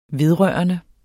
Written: vedrørende

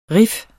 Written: riff

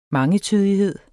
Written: mangetydighed

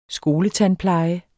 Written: skoletandpleje

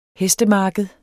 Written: hestemarked

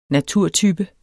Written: naturtype

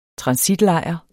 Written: transitlejr

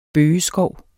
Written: bøgeskov

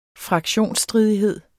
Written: fraktionsstridighed

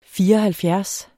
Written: fireoghalvfjerds

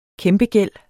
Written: kæmpegæld

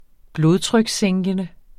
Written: blodtrykssænkende

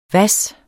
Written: hvas

